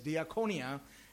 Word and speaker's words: diakonia: Some say diakonia, but the right pronunciation is diakonia.